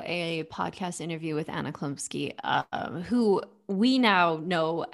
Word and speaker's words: Chlumsky: and and the the child that they got to play this part anna what how do you say her last Chlumsky. name Chlumsky,